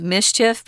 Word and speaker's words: MSCHF: Well, this group called MSCHF, or in English, we would actually just pronounce it mischief because it kind of makes that sound if you say those letters together quickly.